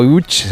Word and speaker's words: Łódź: Aunque se pronuncia Łódź, se escribe Łódź.